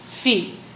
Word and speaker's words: Phi: Ang tawag dito ay Phi. Phi. Oh. Philippines. Oh, yun yung Phi.